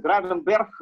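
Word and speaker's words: Gravenberch: Райан Гравенбех, правильно я произношу? – Ну, по-моему, там R читается, Гравенберг э, скорее.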